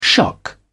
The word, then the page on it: shock: British English